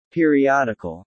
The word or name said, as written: periodical